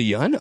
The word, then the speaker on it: Yeun: Was it Yeun? Oh, it's, wow. – Yeah, they actually corrected it in the, in the show.